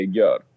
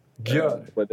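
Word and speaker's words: Győr: Eller hur uttalar man dem? – Alltså i Ungern vet jag inte. Men nej. Jag säger Gyor. – Gör. – Det tror jag alla andra säger också.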